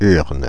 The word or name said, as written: urne